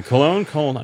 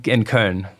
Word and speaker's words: Köln: Cologne. Am I pronouncing it correctly? – In Köln.